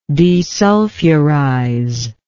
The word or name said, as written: desulfurizer